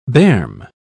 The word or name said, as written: berm